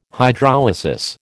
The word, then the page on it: Hydrolysis: American English